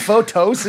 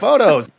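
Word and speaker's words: Fotos: It's not photos, it's photos.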